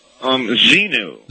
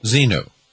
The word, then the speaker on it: Xenu: If I want to pronounce it Zenu or Zendu? – Zenu. – Zenu. – Zenu. X-E-N-U.